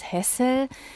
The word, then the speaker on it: Hessel: Sein Vater Franz Hessel, ja, man sprach das H noch aus, also französisch ausgesprochen wurde es erst nach dem Umzug der Familie.